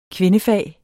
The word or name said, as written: kvindefag